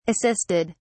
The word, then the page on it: assisted: British English